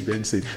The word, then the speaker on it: BNXN: So the way the name is spelled is BNXN, which means Benson.